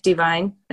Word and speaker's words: Devine: My grandfather was named Frank Devine, and he grew up in Brooklyn, New York. – And it's spelled D-E-V-I-N-E.